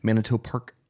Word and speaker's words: Manito Park: And then we'll get into Friends of Manitou more and the event that's going on in June, the Manitou Park Art Festival.